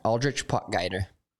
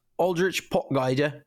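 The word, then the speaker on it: Aldrich Potgieter: Aldrich Pot Geiger. – Yeah, Aldrich Pot Geiger. But yeah, they were calling him Pot Geiger, Pot Geiger. So many people just find a way to butcher that name,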